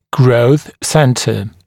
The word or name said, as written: growth center